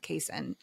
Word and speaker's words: casein: casein.